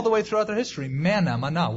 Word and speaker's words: manna: So when the children of Israel saw it, they said one to another, it's manna, or literally, what is it? Manna, pronounced in Hebrew, mana, means, what is it?